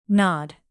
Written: nod

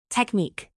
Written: technique

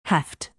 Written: heft

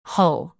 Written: hull

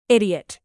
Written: idiot

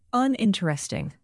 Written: uninteresting

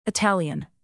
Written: Italian

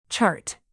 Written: chart

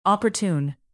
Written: opportune

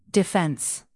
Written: defence

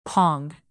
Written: pong

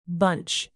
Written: bunch

